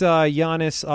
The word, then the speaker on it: Giannis: That Giannis guy is... – Yeah. – He's huge. – He's very good at basketball. – He's gigantic. – That's Giannis ought to be illegal? – Yes.